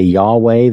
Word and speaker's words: Yahweh: Phonetically, we would say Yahweh the way I have it there.